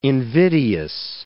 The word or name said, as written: invidious